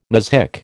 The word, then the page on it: muzhik: American English